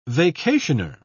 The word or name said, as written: vacationer